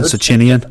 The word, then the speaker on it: Socinian: Socinian? – Yeah, you said.